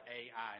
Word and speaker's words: Ai: And so it actually, the word is spelled like Ai, and that's how you actually pronounce it, because I Googled it, and that's why I know that.